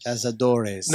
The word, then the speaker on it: Cazadores: Cazadores. – Cazadores. – No, it's Caza. – Caza. – Do. Res.